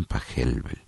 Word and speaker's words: Pachelbel: O algunos dicen Pachelbel, pero bueno, la CH sabemos que, como en el apellido de Bach, la CH se pronuncia como una J, entonces decimos, lo más correcto es decir Johann Pachelbel.